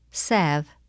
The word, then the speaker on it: salve: The letter L is silent in the following words. Read them and repeat each one during the pause. – Half, halves, salve, could, should, would, Lincoln.